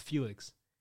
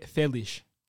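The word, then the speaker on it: Félix: You know, I learned this just today, actually, that it's not pronounced Felix. It's pronounced Felish.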